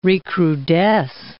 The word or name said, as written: recrudesce